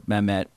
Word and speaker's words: Mamet: Mamet.